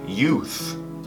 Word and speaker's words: youth: Pronúncia youth, com esse TH de thanks.